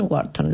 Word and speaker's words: Wharton: además de un MBA en Wharton.